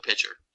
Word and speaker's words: Picher: It's spelled P-I-C-H-E-R. So judging from that spelling, I'm going to go with Pitcher.